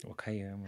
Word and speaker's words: Okayama: É arquiteto, ele estudou na Universidade de Okayama, falei certo? – Okayama.